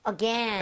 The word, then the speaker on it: again: 발음을 할때 again이라고 하시는 분들이 있는데 실제로 그렇게 발음하는 나라가 없는 거는 아니에요. 오케이. 그렇지만 대부분은 again. – Again. – again처럼 발음하는 게 맞습니다.